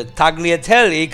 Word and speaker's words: tagliatelle: If you're looking for a rich dish, you may want to try the tagliatelle carbonara. – Like GL is pronounced ye.